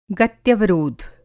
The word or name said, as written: गत्यवरोध